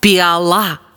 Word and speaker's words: піала: Піала чи піала? Як правильно? Правильно, піала. Наголос на останнє «а».